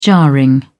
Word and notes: jarring: British English

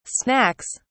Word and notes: snacks: British English

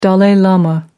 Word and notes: dalai lama: American English

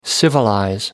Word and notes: civilize: American English

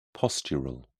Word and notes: postural: British English

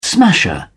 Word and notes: smasher: British English

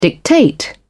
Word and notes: dictate: British English

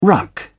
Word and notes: ruck: British English

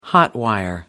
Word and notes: hot-wire: American English

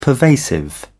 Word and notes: pervasive: British English

